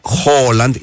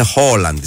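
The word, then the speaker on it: Haaland: Αντός τα παραδείγματα είναι ότι το Χάλαντ προφέρεται Χόλαντ. Είναι δύο, δεν είναι ένα. – Παιδιά, ο ίδιος είπε Χόλαντ. – Όχι, είναι Χόλαντ.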